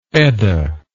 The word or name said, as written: EDA